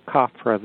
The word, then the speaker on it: Khafre: It's generally attributed to Khafre, the builder of the second pyramid, or Chephron, you can pronounce it that way.